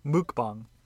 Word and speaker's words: mukbang: Mukbang.